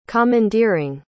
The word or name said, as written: commandeering